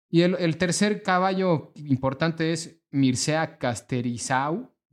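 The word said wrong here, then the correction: Cărtărescu: ¿lo ubican? No. No, perdón, Cartericiu.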